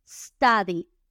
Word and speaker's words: study: La siguiente palabra es la palabra estudio, estudio que se dice en inglés study, S-T-U-D-Y. La U se pronuncia como A en muchas ocasiones.